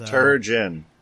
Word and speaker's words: Turgeon: We just added Zach Turgon as our fourth and final headhunter for the event. So. – Turgeon. – Yeah, whatever. – Turgeon. – Rhymes with sturgeon.